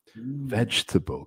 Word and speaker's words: vegetable: Vegetable. ¿Cuántas sílabas en vegetable? Pues, no es vegetable. Vegetable, no.